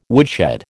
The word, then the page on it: woodshed: American English